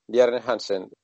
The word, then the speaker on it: Bjarne Hansen: Bjarne Hansen, sí, se pronuncia así.